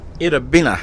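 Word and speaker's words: Itta Bena: is it pronounced Itabina? – Itabina. – Itabina. – Mm-hmm. That's what the natives call it.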